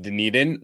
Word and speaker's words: Dunedin: You just correctly pronounced Dunedin, which kind of blew my mind.